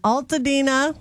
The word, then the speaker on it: Altadena: And mine was in Altadena. Am I saying that right? – Yep.